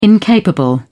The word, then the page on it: incapable: British English